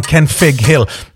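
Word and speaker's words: Kenfig Hill: Are people saying it wrong? Ken Fig Hill. Nope, that's absolutely right. Ken Fig Hill with an F. Because the original Welsh is Mynydd Cynfig, which is a double F.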